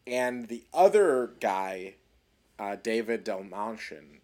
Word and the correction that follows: Dastmalchian: or how do you say his name? – Taylor. – Dastmalchian? Yes. – Dastmalchian? – Malchian. Interesting, sorry.